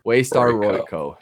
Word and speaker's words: Waystar Royco: Who do you guys think the end of this season is going to be head of Waystar Roke? Oh my gosh, did I just say that wrong? Waystar Royco. Yeah, who do you think is going to be CEO end of the season? – No one that we've seen so far.